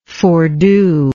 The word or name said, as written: foredo